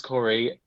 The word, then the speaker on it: Cawrey: Yeah, Aidan Reece Cawrey. The last name like is spelled weird compared to how it's said. It's not like C-O-R-E-Y how it should be, but yeah.